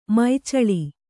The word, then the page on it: ಮುಚ್ಚೂಳು: muccūḷu